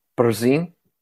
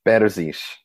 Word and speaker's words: Berzins: Is it the last name is pronounced Berzin? – Sure. Yeah. Oh, let's go with that. That sounds great. – Tell me the real way to pronounce it. – Berzins. – Berzins. Okay. – Oh, not bad. That was close.